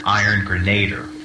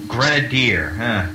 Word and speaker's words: Grenadier: First of all, I must say that for 20 years I've been pronouncing the name wrong because I've always said Iron Grenader. Yeah. So it's Grenadier. Like the drink, Grenadine.